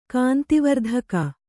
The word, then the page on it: ಕಾಂತಿವರ್ಧಕ: kāntivardhaka